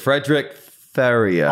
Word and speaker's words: Fredrik Ferrier: I was wondering how you're going to pronounce it, Brissett. You went Fredrik Ferrier. – I have been rehearsing it and I've been studying it and I thought it is Ferrier, isn't it? – You nailed it.